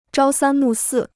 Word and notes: 朝三暮四: zhāo sān mù sì

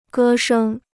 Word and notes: 歌声: gē shēng

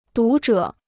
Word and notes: 读者: dú zhě

